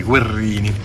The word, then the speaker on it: Guerini: E abbiamo Guerini, quello che si legge, si scrive con una R ma si legge con due R, Guerrini.